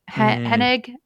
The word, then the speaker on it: Hennig: And then there's the other Star Wars game by the director, writer, Amy Hennig.